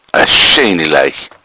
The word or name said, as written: leich